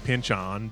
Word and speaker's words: Pynchon: I'm saying Pynchon because that is apparently how he pronounces his name. We know this from a Simpsons episode that he appeared on once.